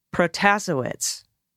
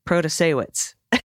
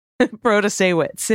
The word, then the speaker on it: Protasewicz: Hi, just a friendly correction for the pronunciation for Judge Janet in Wisconsin. Protasewicz. No, that's not right. Protasewicz. Protasewicz. And here's a video to help you out.